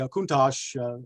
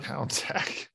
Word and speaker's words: Countach: So it wasn't until much later in life that I realized it was all that those cars from all those posters was actually pronounced Countach and not Countach. But Countach.